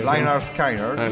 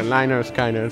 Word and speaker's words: Lynyrd Skynyrd: Lynyrd Skynyrd. – Lynyrd Skynyrd, sì.